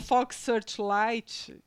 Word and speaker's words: Fox Searchlight: Fox Searchlight, eu falei certo? – Falou.